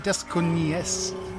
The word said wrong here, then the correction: Desconocida: Adios Desconocida.